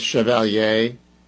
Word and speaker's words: Chevalier: And I pronounce it the French way, Chevalier. I don't know if it's Chevalier, like the way, you know, but think about Chevrolet. That's the way I'm going with it.